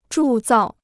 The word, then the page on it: 铸造: zhù zào